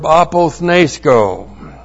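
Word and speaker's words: apothnēskō: And then we have the verb apothnesko. Looks like that in the Greek. A-P-O-T-H-N. Long E, S, K, long O.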